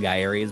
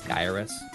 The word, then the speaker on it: Gyruss: Guy, I, I always call it Gyres, but it's like and pronouncing the ad, I think it's Gyruss. – That's what? that's that's what the ad says. – That's that's what the the uh, the ad says to Says how to pronounce it so I I trust.